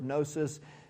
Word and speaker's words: gnosis: The Greek word for knowledge, some of you know, is the word gnosis. The G is silent, but G-N-O-S-I-S, gnosis.